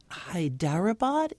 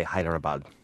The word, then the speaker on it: Hyderabad: I've heard people say that. I've also heard people say Hyderabad. So I usually just say Hyderabad.